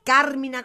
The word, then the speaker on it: Carmina: Entonces no es, es Carmina con acento en Carmina, Carmina Burana, y no es una y No es una ópera porque ¿qué es una ópera?